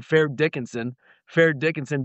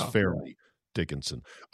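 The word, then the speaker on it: Fairleigh Dickinson: I just can't shake my head of the little guys from Fair Dickinson beating Zach Edey on those screens. – Fair Dickinson. I love how you butchered the name on purpose, because I know you know it is Fairly Dickinson.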